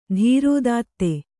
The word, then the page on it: ಧೀರೋದಾತ್ತ: Kannada